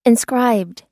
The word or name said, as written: inscribed